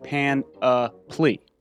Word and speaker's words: panoply: For those following along at home, P-A-N-O-P-L-Y. – Panoply. – However, pronounced like pan-a-ply.